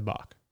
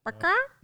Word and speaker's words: balk: You said it right. – It's just strange. – Balk. It's like walk, but with a B. – Baka? – No, that's not it.